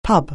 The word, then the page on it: pub: anglais